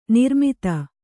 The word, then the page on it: ನಿರ್ಮದೆ: nirmade